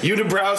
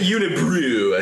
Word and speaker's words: Unibroue: Unibrow spelled with an AU. – Yeah, it's UNIBROUE. – Yeah, Unibrow. – Unibrew, I think is.